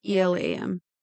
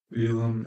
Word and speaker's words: Elam: I think that's how you say it. E L A M. – Elam, Elam.